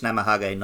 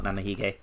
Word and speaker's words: Namahage: I think I've been saying it wrong. I think it's Namahage, not Namahige, but whatever.